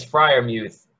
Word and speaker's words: Freiermuth: He could be a – what's with the name pronunciation in this? – It's been said. It's Freiermuth.